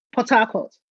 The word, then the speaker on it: Port Harcourt: Port Harcourt. So port is one word and Harcourt is the second word. So almost like an English word.